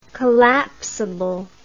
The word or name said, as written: collapsability